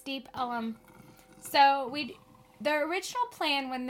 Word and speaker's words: Deep Ellum: That's how you say it. You say Deep Ellum. – No, you don't. – Yeah, you do. It all runs together.